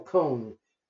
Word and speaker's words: Cone: He was the boss, and he pronounced his name Colonel Cohn very carefully when he answered the phone. Colonel Cohn, C-O-N-E.